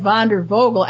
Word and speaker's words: Wandervogel: Okay, and explain what Wanderfugel, as you pronounce it correctly, but I would say Wanderfugel, but it looks like Wandervogel. Actually, it's Vogel is birds.